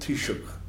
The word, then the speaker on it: Taoiseach: Our president is, our prime minister has given an old Gaelic name, which is called Taoiseach, which phonetically is like tea and shock.